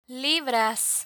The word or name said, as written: libras